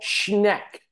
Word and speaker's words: Schneck: But we would like to introduce Nicole Schneck. Am I pronouncing that correct? – Yes, yes, you are.